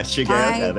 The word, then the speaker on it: Shigeoka: I put the gay in Shigeoka. Yeah, I put the gay in Shigeoka. Yeah, Shigeoka.